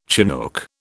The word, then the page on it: chinook: British English